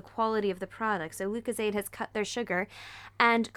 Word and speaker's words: Lucozade: I am opening Lucozade. Pronounced that right, yeah? Mm-hmm.